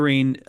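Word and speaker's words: Lérins: I want to say it, the rule of St. Vincent of Lerene. Is that how you say it? – Yeah. Mm-hmm. – St. Vincent of Lerene, that is universality, antiquity, and consent.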